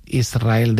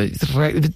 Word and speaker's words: Israel: Y de diminutivo, de chiquitito, Israel, que ella quería... porque Israel creo que le sonaba muy grande, ¿no? Israel, ¿no? – Y la S con la R es difícil de pronunciar.